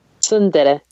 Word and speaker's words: tsundere: Tsundere.